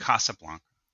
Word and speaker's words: Casablanca: Or is it Casablanca? How do you pronounce it? – Casablanca. – I got it right.